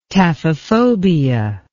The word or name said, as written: taphephobia